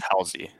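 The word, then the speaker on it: Halzle: Is it Housie? How do you pronounce his name? Is it Joe Hasley? – Housie. – That's what I thought. It always sounds wrong when I say Housie.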